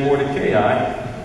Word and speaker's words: Mordecai: How do we say it, Wendell? Mordecai? Wendell says where we come from, we say Mordecai.